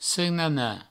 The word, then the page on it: senanar: senana